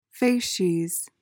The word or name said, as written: facies